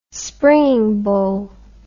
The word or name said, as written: springingbow